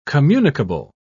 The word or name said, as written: communicable